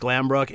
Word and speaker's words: Glanbrook: I was saying that wrong. Niagara West Glanbrook.